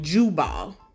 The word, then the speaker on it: Jubal: Jabal, okay, and Jubal. J-A-B-A-L and J-U-B-A-L.